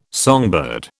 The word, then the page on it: songbird: British English